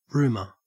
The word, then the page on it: rumour: Australian English